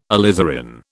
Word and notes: alizarin: British English

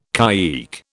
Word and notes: caique: British English

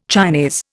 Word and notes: Chinese: Indian

